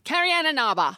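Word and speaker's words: Carrie Ann Inaba: Yeah, but she was done before, I think, Kariana Naba. – Okay. – Because she was done being a fly girl by 92, I think. – You didn't say her name right. – Kariana Naba.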